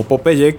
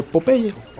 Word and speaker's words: Popeye: Cabe señalar que todo mundo en español conocemos a Popeye como Popeye, que no se pronuncia así porque su nombre en inglés es Popeye, de que le brinca el ojo.